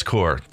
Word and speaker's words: Corps: The Jedi DJ Corps, or is that pronounced core? Yeah, I think it's pronounced core. It's core.